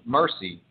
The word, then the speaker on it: Mercie: Mercie. Yeah, her name is Mercie. The spelling throws people off sometimes. The I-E on the end.